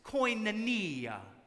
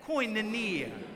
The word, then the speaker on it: koinonia: It's, it's koinonia. Say that, koinonia.